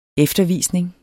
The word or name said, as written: eftervisning